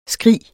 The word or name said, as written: skrig